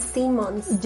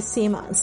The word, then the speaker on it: Simmons: Simpson. J.K. Simmons. J.K. Simmons.